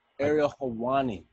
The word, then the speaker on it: Helwani: How do you pronounce his name? – I think it's Ariel Helwani. – Ariel Helwani, that's how it is.